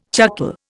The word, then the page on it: chuckle: Indian